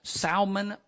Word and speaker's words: salmon: Salmon patties. By the way, the L is silent for you people. It's salmon.